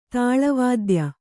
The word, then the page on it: ತಾಳವಾದ್ಯ: tāḷa vādya